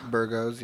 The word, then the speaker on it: Burgos: Shane Burgos. Is that how you say it, right? And Edson Barboza? – Burgos, yeah.